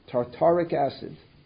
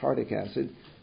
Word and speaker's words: tartaric acid: The first of these is cream of tartar and tartaric acid. I mispronounced, I said tartic acid. Tartaric acid.